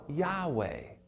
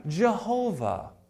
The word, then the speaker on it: Yahweh: And instead of the Y being Yah, it became Jah, and now we have Jehovah. So Yahweh, Jehovah are the same names, just different syllables, inserting different vowels.